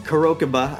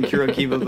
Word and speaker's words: Kurokiba: And Kurokiba. I guess I've been saying that wrong. I've been saying Kurokiba, but Kurokiba.